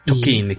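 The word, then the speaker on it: Tocchini: Tokini, que ya estuvimos checando la pronunciación, ¿verdad? Correcto, Hicimos nuestra tarea.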